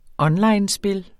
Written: onlinespil

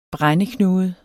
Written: brændeknude

